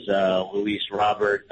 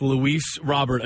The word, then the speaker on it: Luis Robert: So, but I've always heard as Luis Robert. – Well, then we will go with Luis Robert until, I guess, Luis tells us differently.